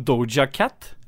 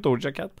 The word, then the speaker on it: Doja Cat: Har du hørt om Doja Cat? Jeg tror det er riktig uttale. Sjukt kul, catchy låt fra en artist jeg ikke har hørt om før. Doja Cat.